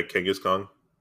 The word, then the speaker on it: Kangaskhan: which is the secret of Kangaskhan. Or Kang Kang did I say that right? Kangaskhan? Yeah, Kangaskhan. I said that right.